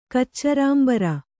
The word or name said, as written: ಕಚ್ಚರಾಂಬರ